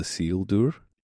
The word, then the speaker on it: Isildur: So I once said, I play a character called Isildur. It's like a weird pronunciation, but that's the real pronunciation of the name.